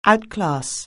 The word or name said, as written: outclass